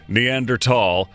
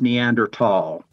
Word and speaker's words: Neanderthal: But yeah, to start, Neanderthal, and it's tall, not thal. – The word is always pronounced Neanderthal.